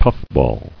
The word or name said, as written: Puffball